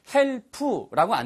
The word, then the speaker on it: help: 발음 하나만 주의하실까요? help라고 안 들리고요. help, help 이렇게 들립니다. 마치 hope 하는 것처럼 그런 느낌의 예, p 발음이에요.